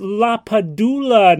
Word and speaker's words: La Padula: And I played that for him, and his wife goes, "It's La Padula, not La Padula." And she was really offended that we mispronounced his name. So I think that's why they didn't like the song, because Rob mispronounced the name. He didn't get the correct Italian pronunciation of La Padula.